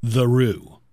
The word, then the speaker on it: Theroux: there's no way that Justin Theroux pronounces his name Justin Theroux. – Justin Theroux. Justin Theroux. – No, but is that bullshit? – No, that is totally true.